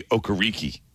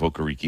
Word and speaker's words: Okereke: His first half of his career, he was Bobby Okereke. And then he decided, you know, it was not pronounced that way. He called himself Okereke, and then it was going to be Okereke.